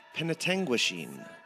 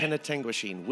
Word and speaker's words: Penetanguishene: And the first one is pronounced Penetanguishene. – Yeah. – Penetanguishene, which is in the north of Ontario. It's kind of cottage country, lakey, all that area. If you go up to the town of Penetanguishene, it's beautiful cottaging in the summertime with the beautiful lakes and that sort of thing.